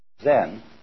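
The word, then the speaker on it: zen (禅): Zen is a translation of the Sanskrit word jhana, pronounced chan in Chinese and zen in Japanese.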